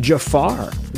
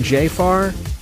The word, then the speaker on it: Jaafar: I want to say his name's pronounced Jafar. Maybe Jafar? J A A F A R Jackson's going to portray his late uncle.